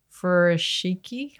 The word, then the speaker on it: furoshiki: There's a great technique, a Japanese technique called furoshiki. I don't know if I'm pronouncing that right. – Yeah.